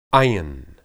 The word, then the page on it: ayin: hebrew